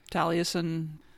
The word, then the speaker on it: Taliesin: So they're holed up at the Spring Green, Wisconsin, Taliesin. Did I say that right? – Taliesin. I'm not sure. – Oh my God. It's Taliesin.